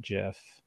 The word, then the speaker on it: GIF: is pronounced GIF and not JIF, even though the man who invented it says that it should be pronounced JIF.